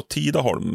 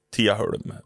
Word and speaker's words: Tidaholm: Och det heter ju för rikssvenska då Tidaholm, men det uttalas Tiaholm. Viktigt.